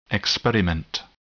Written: experiment

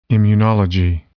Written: immunology

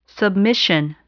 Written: submission

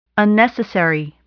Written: unnecessary

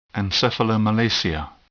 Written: encephalomalacia

